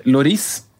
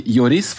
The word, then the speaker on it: Lloris: Først et navn, keeperen, som er velkjent, men han har hørt han uttale navnet sitt selv, Lloris. – Oh, ja. – Men man kan jo sikkert si Lloris, for det er dobbelt L. Ja. Kan kanskje også si Jori.